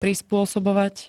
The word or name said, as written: prispôsobovať